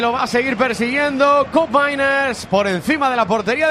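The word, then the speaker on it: Koopmeiners: Por si alguien tiene dudas, como se escriben muy raros, hemos estado mirando la pronunciación antes del partido. Cup Miners, el pivote. – Cup Miners.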